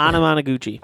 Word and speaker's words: Anamanaguchi: Anamanaguchi. – Anamanaguchi.